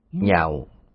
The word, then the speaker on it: Nhạo: Nhạo, ở đây chúng ta đọc là nhạo.